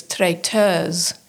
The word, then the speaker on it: traiteuse: And the female form of the word is traiteuse. – S-E. – It sounds like a Z sort of at the end, if I'm even pronouncing it right, but I think I am. It ends in S-E, but it's traiteuse.